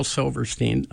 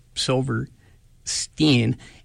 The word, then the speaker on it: Silverstein: First of all, it is Shel Silverstein. The pronunciation does not follow the Germanic rules of consonants. It is Shel Silverstein.